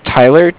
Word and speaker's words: Tyler: My name is Tyler, T-Y-L-E-R. – Okay. – And I'm calling with Cardiff. It's spelled C-A-R-D-I-F-F, as in Frank.